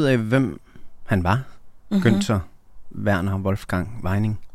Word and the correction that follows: Weinig: Ja, altså man staver det med G, men man udtaler det som ich, Weinig.